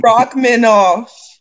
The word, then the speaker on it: Rachmaninoff: Miss Tiffany. Rachmaninoff. That's how you say it.